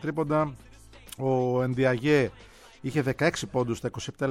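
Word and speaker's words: Ndiaye: είναι πλοκάμια του Ενδιαγέ ή Ενζάι, όπως το προφέρει ο ίδιος, έτσι λίγο αμερικάνικα.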